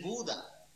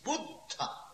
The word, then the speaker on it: Buddha: La palabra, ustedes dicen Buda. Buda no significa nada. Si significa algo, es completamente opuesto. Es Buddha.